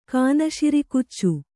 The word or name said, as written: ಕಾನಶಿರಿಕುಚ್ಚು